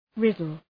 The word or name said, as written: riddle